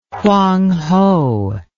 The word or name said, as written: hwang ho